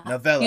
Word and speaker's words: Novella: Novella. You said it right the first time, yes. Like a short novel.